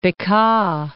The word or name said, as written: Bekaa